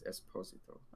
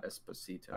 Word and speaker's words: Esposito: Apparently, that's how you say it, Esposito, not Esposito.